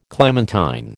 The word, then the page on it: clementine: American English